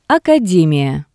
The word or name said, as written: academiya